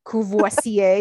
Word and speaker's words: Courvoisier: I'm putting a car payment down on Kwasi. Am I saying it right? – Courvoisier.